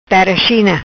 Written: China tera

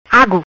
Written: Agu

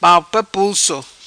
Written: palpa pulso

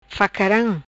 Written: Facaram

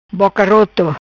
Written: Boca Roto

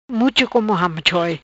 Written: Mucho Como Ham Choi

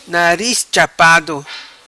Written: Nariz chapado

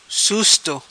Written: Susto

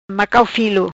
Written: Macau filo

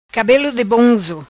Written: Cabelo di bonzo

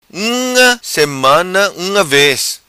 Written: Unga Semana

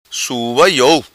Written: Sua Iou

